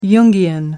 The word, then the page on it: jungian: American English